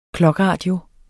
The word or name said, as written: clockradio